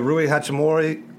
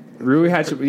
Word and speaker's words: Rui Hachimura: Marcus Howard, you know, Rui Hachimura. Did I say that right? – Rui Hachimura.